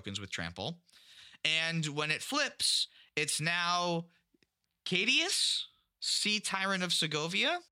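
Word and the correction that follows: Caetus: I was so proud. I was like, I haven't mispronounced a word yet, but it's Cadus.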